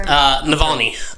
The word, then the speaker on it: Navalny: How do you say his last name? – Navalny.